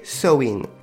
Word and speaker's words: Samhain: Alors, pour écrire Samhain, la, fête celtique, euh, si je le dis en français, si je le lis en français, ça s'écrit Samhain, main ça main, ça... Voilà, c'est imprononçable. Donc du coup, la prononciation correcte, c'est Sowin Voilà,